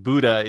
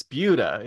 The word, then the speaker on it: Buda: Like, do not pronounce it Buddha. It's Buddha. And I thought, okay, I'll remember that. – I love that. Yeah. No, it's German, actually.